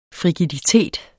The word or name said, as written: frigiditet